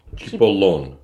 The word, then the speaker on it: cipollone: How do you say it? – Cipollone. – Cipollone.